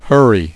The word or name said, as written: hurry